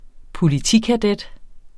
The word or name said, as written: politikadet